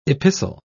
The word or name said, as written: epistle